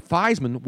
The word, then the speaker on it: Theismann: But they didn't want to put Theismann, so they changed it to Theismann, which rhymed with Heisman.